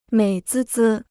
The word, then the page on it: 美滋滋: měi zī zī